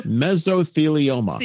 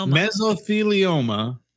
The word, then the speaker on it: mesothelioma: If you've got mesothelioma. What is that? How do you say that? – Mesothelioma. – Mesothelioma.